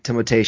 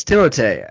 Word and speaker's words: Timothée: One of my personal favorite actors working, Timothée Chalamet. – But you mispronounce his name. – You know, it's Timothée. It's Timothée because he's French. That's how they pronounce it.